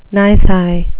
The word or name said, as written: nisi